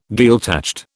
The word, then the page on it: Gaeltacht: British English